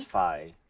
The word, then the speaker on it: Fi: It looks like in Japanese it is Fi.